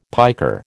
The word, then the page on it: piker: American English